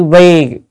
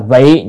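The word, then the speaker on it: vi (為): Chữ này chữ vi hoặc là vị, nhưng mà ở đây phải đọc là vi, là. Chứ nếu đọc vị là vì thì không được.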